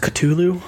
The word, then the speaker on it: Cthulhu: Uh, it's pronounced Cthulhu, actually.